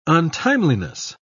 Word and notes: untimeliness: British English

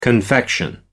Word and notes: confection: American English

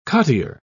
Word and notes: cottier: British English

